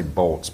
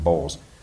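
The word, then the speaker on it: Bolz: Sean Bowles, you attempt to say Bolts, but he actually pronounces it Bowles.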